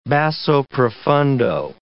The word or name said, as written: basso profundo